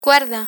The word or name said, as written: cuerda